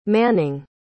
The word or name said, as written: manning